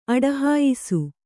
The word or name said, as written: ಅಡಹಾಯಿಸು